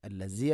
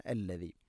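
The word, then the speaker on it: അല്ലദീ (الذي): ഹുവല്ലാഹു അല്ലദീ ഹുവല്ലാഹു അല്ലദീ അല്ലദീ, ദാലിൻ്റെ ഉച്ചാരണം ശ്രദ്ധിക്കുക. അല്ലസി അല്ല അല്ലദീ.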